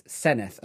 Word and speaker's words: Senedd: which in Welsh is Senedd. And I think that is S-E-N-E-D-D, but I could be wrong, but it's spelt Senedd, but it's pronounced Senedd.